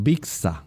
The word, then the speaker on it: Bixa: Dijimos que su nombre botánico es Bija Orellana, pero en este caso, en términos taxonómicos y de acuerdo al nombre, Bija se escribe B-I-X-A, Bija. Algunas personas le dirán Bixa, pero se puede decir Bixa o Bija, Bija Orellana, y el Vicksin, así se le llama a este carotenoide, o Neovicksin.